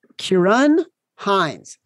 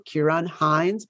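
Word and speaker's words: Kieran Hinds: Kieran Hines. I looked it up. I want to make sure I was pronouncing it correctly because it's Gaelic. Kieran Hines, Belfast, +1800.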